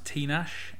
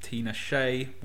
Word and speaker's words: Tinashe: And there's a new album by, I think her name is pronounced Tinashe, not to be confused with Tina Shea, with an accent on the E, who was kind of a crappy singer in the 90s.